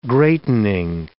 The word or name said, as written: greatening